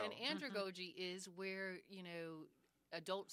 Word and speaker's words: andragogy: And in your paper, you had a section on how adults learn, and you used the word andragogy. What's that? Did I pronounce it correctly? – You did, andragogy.